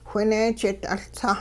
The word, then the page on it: whineejit-'uLtsah: Hupa